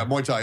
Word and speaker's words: Muay Thai: Boxing or like Muay Thai. Am I saying that right? I don't know. – Yeah, Muay Thai, yeah.